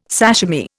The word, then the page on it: sashimi: Indian